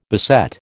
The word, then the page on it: beset: American English